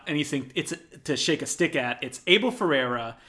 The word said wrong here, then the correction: Ferrara: Am I saying his name wrong still? – Yeah, Ferrara. – Ferrara.